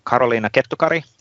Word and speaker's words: Karoliina Kettukari: Um, today uh, we have Karoliina Karoliina Kettukari. That's a Finnish way of pronouncing that. You can do Karoliina Kettukari even in, in English.